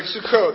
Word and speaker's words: Sukkot: Sukkot, I think is how you pronounce it. Is that right? Sukkot, there we go.